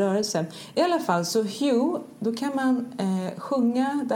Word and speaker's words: Hu: Det uttalas Hu men stavas H-U, Hu, och det är ett gammalt namn för Gud, och det kommer från Ekankar, religionen om själsfärder.